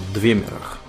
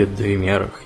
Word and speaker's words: Двемер: Двемер, да. Двемер, Данмер, Альтмер – Угу. Теутарин всегда на первый слог, который означает какой, а не на второй, который означает эльф.